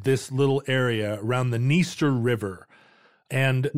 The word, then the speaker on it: Dniester: You might want to pronounce this the Dniester River, but the D is silent.